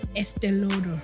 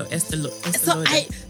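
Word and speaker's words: Estée Lauder: But so then yesterday I went and I tried on Estee Lauder. – Oh, nice. – I can't even say it. Is that how you say it? – I think so. Estee Lauder.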